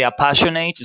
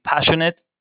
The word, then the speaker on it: passionate: You don't say appassionate, you say passionate.